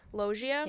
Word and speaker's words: Loggia: Loggia.